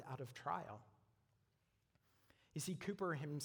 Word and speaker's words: Cowper: So William Cooper, it's spelled Cowper, but it's actually pronounced Cooper.